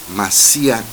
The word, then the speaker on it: Macía: Macía, con C y acento en la última.